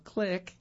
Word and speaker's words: Klick: The final category includes choices like the Firefly, Batek, Klaxon Click, that's spelled K-L-I-C-K, Tri-Ride, and Impulse F35, which consist of an extra motorized wheel mounted to the front of a wheelchair.